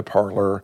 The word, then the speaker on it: Parler: Called Parler, P-A-R-L-E-R. – Okay. – The Q is silent.